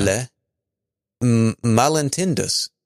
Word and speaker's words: Les Malentendus: L-E-S. – Yeah. – Les Malentendus. M-A-L-E-N-T-E-N-D-U-S.